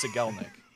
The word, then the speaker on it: Cigelnik: It's Cigelnik.